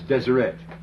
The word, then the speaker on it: Deseret: The word holy here is written very phonetically, Deshereo. It's Deseret. It's the word for holy, remember, set apart, sanctified, Deseret.